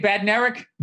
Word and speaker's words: Bednarik: Bednarik? – Bednarik, yeah.